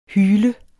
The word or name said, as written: hyle